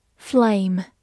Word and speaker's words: flame: Flame, F L A M E, flame，字母 a 发它本身的音 a，末尾的 e 不发音。